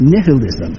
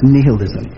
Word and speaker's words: nihilism: There is one other way of thought, and it's called, I'll pronounce it the Latin way, but I think it's pronounced differently ordinarily. It's nihilism. I've heard it pronounced nihilism, but nihil in Latin means nothing. Nothing.